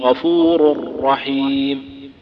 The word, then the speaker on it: غفور الرحيم: فمثلا غفور الرحيم، نطقي للراء الثانية في رحيم أشد أطول صوتا من نطقي للراء الأولى في غفور، كذا تقول والله غفور الرحيم، غفور الرحيم، فيها تكرار أكثر وفيها صوت أطول لأنها مشددة،